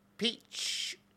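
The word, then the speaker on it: Pietsch: So there's a lady, Brittany Peach. Okay. Pronounced the wrong way. I mean, you'd think Peach would be P-E-A-C-H, but that's P-I-E-T-S-C-H.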